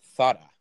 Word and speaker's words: Zara: Oh, I knew Zara was from Spain. – Yeah. – So it's they just opened one. – Actually pronounced Thada.